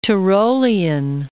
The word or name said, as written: Tirolean